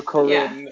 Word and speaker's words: Corinne: Corinne, I think. That's Corinne? How Corinne on The Bachelor. – Yeah, like even she still plays spelled the same way.